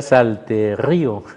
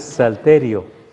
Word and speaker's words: salterio: Sí, No tiene acento en la i para que sea salterío, entonces salterio, que sería entonces la colección de cantos.